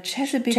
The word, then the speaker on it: Chesapeake: Spricht man das echt Chesapeake? – Also laut dem ja, so haben die das genannt. Ja. Also Chesapeake, Chesapeake Shores.